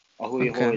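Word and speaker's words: a hui hou: Ahui hoi is bye. – Interesting. Ahui hoi. Am I pronouncing it right? – Ahui ho. – Ahui ho. Yeah, I got it.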